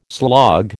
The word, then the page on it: slog: American English